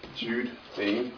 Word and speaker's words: Jude Bain: Jude Bain. – How do I spell that? – J-U-D-E B-A-I-N.